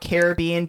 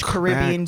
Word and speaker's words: Caribbean: and we are talking about Caribbean Beach, Caribbean Beach, however you want to pronounce it. – It's up to you. – I'm a Minnesotan. I go both ways.